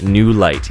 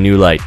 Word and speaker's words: Nulight: Anyways, these two, they call themselves Tacky and New Light. Yes, Tacky and New Light. Tacky is spelled T-A-I-K-I. And New Light, N-U-L-I-G-H-T.